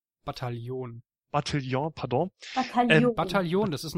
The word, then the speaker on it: Bataillon: Bataillon, sag's einfach auf Bataillon. – Deutsch. – Bataillon. – Ja. Sag's einfach auf Deutsch, das ist gar nicht so schwer.